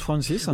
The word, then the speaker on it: Frances: Ouais, je pense que ça se prononce Francis.